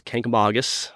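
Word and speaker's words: Kancamagus: How do visitors pronounce it? – Wrong. mostly it's Kankamagus. – Kankamagus. – Is what they say, Kankamagus. – Kankamagus. – But it's Kankamagus.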